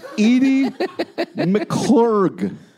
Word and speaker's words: Edie McClurg: Edie McClurg. – Spelling? – It's M-C and then a big C, L-U-R-G.